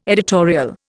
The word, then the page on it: editorial: Indian